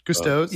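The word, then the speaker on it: Gusteau's: How do you pronounce Gustav's? – Gusto's. – Yep. – Gusto's.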